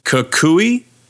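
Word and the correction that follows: Kakuei: I would think that's Kakue. Yeah, Kakue Tanaka.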